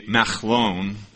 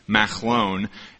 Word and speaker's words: Machlon: Her son's names, the first one, the actual pronunciation would be Machlon. I'm not very good with the guttural sound, but Machlon.